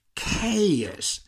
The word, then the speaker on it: chaos: Y la siguiente, caos, que se escribe chaos, caos, este es un caos, este es un desastre. Caos, pues es chaos.